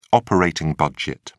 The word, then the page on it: operating budget: British English